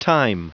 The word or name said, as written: thyme